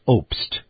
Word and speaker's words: Obst: Here's the host of the program, Carol Marx, front and center, in conversation now, a two-parter for you, with Linda Obst. That's spelled O-B-S-T, Linda Obst.